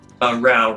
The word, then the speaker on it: Rao: Rao rhymes with cow.